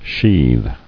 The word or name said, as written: Sheather